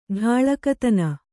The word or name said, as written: ಡಾಳಕತನ